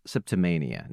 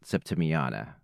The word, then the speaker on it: Septimania: And yes, I was saying that name wrong last time. It's Septimania, not Septimiana.